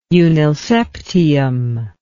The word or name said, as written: unnilseptium